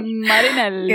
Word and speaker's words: Marinelli: Luca Marinelli, este como es italiano y lo pronuncio mejor, Marinelli.